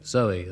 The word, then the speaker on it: Zowie: It's called Kooks and celebrates the birth of a baby on Sunday called Zoe at the moment, spelt, of course, Z-O-W-I-E, to rhyme with Bowie.